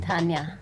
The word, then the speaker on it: Montagne: But this is a French artist, Miel del Montagne. Come on. – Montagne.